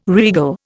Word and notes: regal: Indian

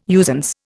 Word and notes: usance: Indian

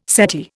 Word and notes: settee: Indian

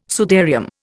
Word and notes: sudarium: Indian

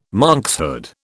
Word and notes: monkshood: British English

